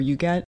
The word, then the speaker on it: Huguette: it's spelled H-U-G-U-E-T-T-E, but I have heard it pronounced Huguette or Huguette. – Mm-hmm. – I will probably butcher it either way, but I think I'm going to go with Huguette, because that's how I heard him say it within the confines of a lecture I watched.